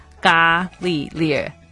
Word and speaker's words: 伽利略: 昨天谈到伽利略的时候，突然记起台大的孙维新教授说过，伽利略的钱并不念成茄子的茄，而应该念成伽利略。用音译来看，OK，不，因为意大利文是 Galileo 嘛。好，所以那你念成家也可以。